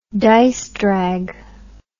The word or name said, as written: disedrag